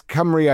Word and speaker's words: Cymru: is it pronounced Cymru? – Yes, Cymru, I think that's the Welsh for Wales.